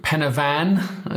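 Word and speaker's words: Pen y Fan: And in the UK as well, particularly here, my beloved Penavan, as we had this conversation. It's not fan, it's van. – It's spelled F-A-N. – Jeez, it's how you pronounce it in Welsh.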